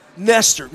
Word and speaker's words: Nestor: Like, if I'm at the coffee shop, she says, what's your name? Nestor. Huh? Nester. Well, that first off, that's not how it's spelled, and it's weird. And if you say rhymes with Lester or, you know, whatever.